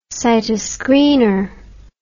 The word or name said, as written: cytoscreener